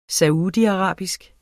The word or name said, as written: saudiarabisk